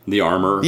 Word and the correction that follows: armorer: Yeah, the armorer. You gotta use all of those syllables in there. Armorer.